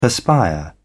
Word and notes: perspire: British English

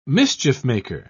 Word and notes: mischief-maker: British English

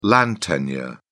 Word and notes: land tenure: British English

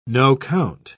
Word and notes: no-count: British English

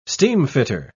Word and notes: steamfitter: British English

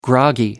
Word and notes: groggy: American English

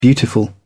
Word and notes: beautiful: British English

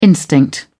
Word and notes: instinct: British English

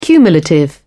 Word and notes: cumulative: British English

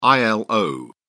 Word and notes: ilo: British English

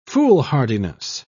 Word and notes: foolhardiness: British English

